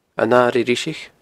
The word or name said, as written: An Airigh Dhriseach